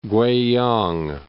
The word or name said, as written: Kweiyang